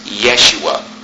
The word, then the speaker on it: Yeshua: The J is pronounced like a Y. How is the J pronounced in Spanish? Like an H, just a puff of air, okay? So this right here, this name right here, okay? Jeshua, or in Hebrew, perhaps it was pronounced Yeshua, right?